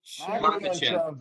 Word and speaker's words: Makhachev: Makhachev, Makhachev baby.